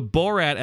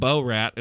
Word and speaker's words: Borat: Speaking of pronunciation, the Borat episode also made me scream on an airplane. That was, for a refresher, that's when Josh said Bo-rat instead of Borat.